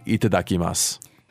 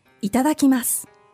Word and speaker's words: itadakimasu: And then also, how do you say itadakimasu? – Itadakimasu.